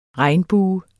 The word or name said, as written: regnbue